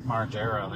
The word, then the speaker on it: Margera: Margera, there you go.